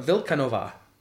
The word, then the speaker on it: Vilkanova: Adam Vilkanova. You heard that correctly. – Vilkanova? – Ova. – Okay. – No long A, but Vilkanova.